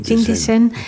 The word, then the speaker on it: Jingdezhen: Dit jaar nog bent u naar China gereisd om daar aan de International Academy of Ceramics in Jingdezhen, zeg ik het goed? – Jingdezhen.